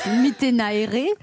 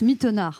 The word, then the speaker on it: Mittenaere: Alors moi je suis hyper stressée de votre nom parce que moi je disais Iris Mittenaere et là on me dit qu'il faut dire Iris Mittener. – Mittener, ouais, c'est flamand. C'est vrai que les gens ont envie de le prononcer à la tahitienne, mais c'est flamand.